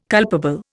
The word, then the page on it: culpable: Indian